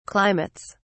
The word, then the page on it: climates: British English